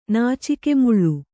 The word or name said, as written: ನಾಚಿಕೆಮುಳ್ಳು